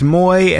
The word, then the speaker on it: Mooij: It's spelled M-O-O-I-J, but it's pronounced Moy, and this is a fun one.